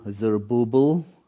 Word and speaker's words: Zerubbabel: In the Old Testament says, you know, the Lord talking to Zerubbabel. That's the right pronunciation. It's a big name.